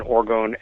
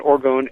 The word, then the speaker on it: orgone: An orgone energy accumulator is an equally deceptively simple device, and the term is properly pronounced orgone, not orgon, which is a gas found in nature.